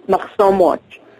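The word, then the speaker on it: Machsom Watch: There's Israeli groups like, how's it pronounced, Maxim? – Maxim Watch.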